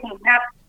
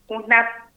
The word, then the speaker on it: Junap: Sería tierra, se podría decir Junap. H-U-U, Junap.